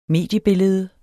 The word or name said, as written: mediebillede